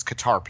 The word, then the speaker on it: Qatar: the Qatar – and it's Qatar people. I hear this other pronunciation. I don't understand. – Qatar? – Qatar. It's a Qatar. Anyway, Quinter or whatever. It's some Americans say it this weird-ass way. It's Qatar. Ah, anyhow, I'm way off topic today.